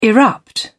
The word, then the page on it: erupt: British English